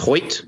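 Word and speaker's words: Foyth: Is it Foyt? – Yes, Foyt. Yeah. – Mm-hmm.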